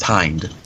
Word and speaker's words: Hind: I'm pretty sure it's actually supposed to be pronounced Hind. – Hind.